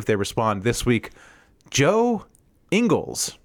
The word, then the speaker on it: Ingles: Pronounced Ingles because they apparently have no respect for other people's culture down in Australia. That last name is clearly Ingles, but they're just like, nah, we're going to call it Ingles because we don't care about your language.